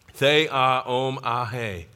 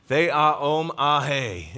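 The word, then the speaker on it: Theaomahe (θεάομαι): I need to read the pronunciation of this one. Theaomahe. Theaomahe is the pronunciation here.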